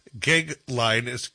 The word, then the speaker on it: Goeglein: It's Timothy, and his last name's Gigline, and it's spelled GOEGLEIN.